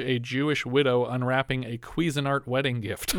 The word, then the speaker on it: Cuisinart: We looked it up. – No. – Yes. I Googled it. I played a YouTube video for you. – I wasn't listening. – It's pronounced Cuisinart.